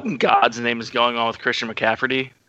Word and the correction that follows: McCaffrey: Well, well, he doesn't have a T in his last name for one. – There, Did I did I spell it wrong? if not, you at least pronounced it wrong.